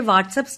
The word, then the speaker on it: WhatsApp: ഞാൻ കൃത്യമായി എന്റെ വാട്സ്ആപ്പ് സ്റ്റാറ്റസ് അപ്ഡേറ്റ് ചെയ്യാറുണ്ട്. നല്ല കാര്യം. എന്നാൽ ഉച്ചാരണത്തിൽ പിശകുണ്ട്. WHATSAPP വാട്സ്ആപ്പ് ആണ്, വാട്സ്ആപ്പ് അല്ല.